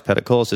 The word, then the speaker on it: Peticolas: I always thought it's cool, but it had a flaw, you know, until you hear it pronounced Peticolas, it's kind of, you know, how do you say that? You know, people would struggle over how, you know, you pronounce it,